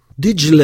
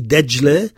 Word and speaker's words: Dijle: Dijle, et on prononce aussi Dajle, c'est le Tigre, le fleuve du Tigre, et qui prend sa source en Turquie et qui finit à Bassora.